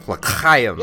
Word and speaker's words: L'chaim: You got to get that, like, almost like you're bringing up phlegm. L'chaim. Yeah. L'chaim.